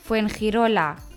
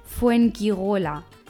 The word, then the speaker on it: Fuengirola: Repito, Fuengirola con G. Die Deutschen würden sagen Fuengirola.